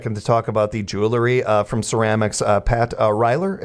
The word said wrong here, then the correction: Ryer: am I saying that right? Ryler? – Pat Ryer. – Ryer, yes.